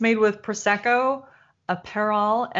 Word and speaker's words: Aperol: Apparel. I'm not wearing it, so it must be Aperol.